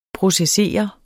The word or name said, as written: processere